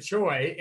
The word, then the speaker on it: Choi: Uh, top tier Springer, uh, Ji-Man, uh, Chow or Choi, sorry, in the I knew I was going to butcher that one. Uh, Ji-Man Choi in the mid tier and Rougned Odor for value.